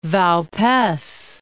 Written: Vaupes

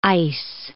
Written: ice

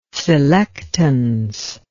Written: selectance

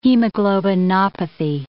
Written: hemoglobinopathy

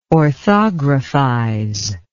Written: orthographize

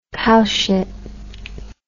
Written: cowshit

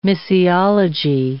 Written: missiology

